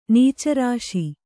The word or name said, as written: ನೀಚರಾಶಿ